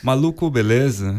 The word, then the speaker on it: Maluco Beleza: How to pronounce it? Maluco Beleza? – Maluco Beleza.